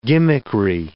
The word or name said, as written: gimmickry